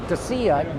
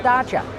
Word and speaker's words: Dacia: I know it looks like Dacia, but it's actually pronounced Dacia.